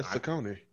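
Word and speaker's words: Saucony: Are we saying the name right? – Yeah, Saconis.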